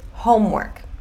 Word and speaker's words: homework: Homework, tá? Só duas sílabas.